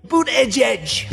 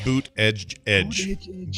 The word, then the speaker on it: Buttigieg: By the way, Buttigieg, we got to start saying his name right. – Buttigieg. – Buttigieg. Buttigieg. – You know, I don't think that's right, man. Like I listened to like him and his husband say their last name, and it doesn't sound like Buttigieg.